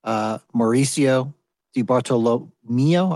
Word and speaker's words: Mauricio Di Bartolomeo: We've also got the Chief Strategy Officer of Ledn and founder, Mauricio Di Bartolomeo.